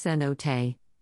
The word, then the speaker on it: cenote: Pronounced cenote,